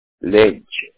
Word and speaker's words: Lynch: Kenneth, K-E-N-N-E-T-H, Lynch, L-Y-N-C-H.